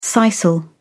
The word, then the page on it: sisal: British English